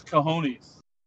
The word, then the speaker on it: cojones: I don't mind making predictions because if I'm wrong, I'll just say, hey, at least I have the kahunas to throw it out there to try to be right, you know. But kahunas. – Is that how you say it? – I think it's cojones.